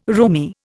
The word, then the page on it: roomy: Indian